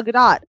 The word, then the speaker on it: Gadot: She pronounced it at the at one of the screeners where she said it's Gal. It's a hard T. It's not a soft T. – Oh, it is Gal Gadot.